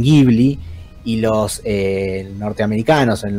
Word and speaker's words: Ghibli: la cuñan de esa forma, Ghibli, así es como deberíamos pronunciarla.